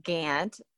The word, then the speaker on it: Gant: And my father, Turner Maurice Gant, spelled like Gaunt, but we say Gant in Texas, was a graduate of West Point, of the United States Military Academy at West Point.